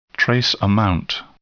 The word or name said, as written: trace amount